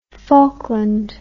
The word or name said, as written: faulkland